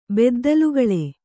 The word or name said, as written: ಬೆದ್ದಲುಗಳೆ